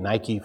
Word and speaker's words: Nike: We get Nike from that. Nike is, the Greek pronunciation of that.